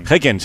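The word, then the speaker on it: Häcken: Haken, se pronuncia así. Haken, es verdad, si te he oído con Paco, juega a mediodía.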